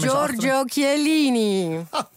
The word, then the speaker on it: Chiellini: Chiellini. – Maar ja, niemand kan het zo mooi uitspreken als jij. Dus zeg jij nog een keer zijn voornaam. – Giorgio Chiellini.